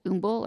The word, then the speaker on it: Humble: It's called Humble, or in Houston they pronounce it Umble or something of the sort.